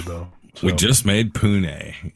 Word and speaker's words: Pune: It is probably Pune, I would say.